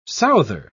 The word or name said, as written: souther